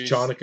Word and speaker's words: Chanukah: He keeps mispronouncing Hanukkah. – It's just Chanukah.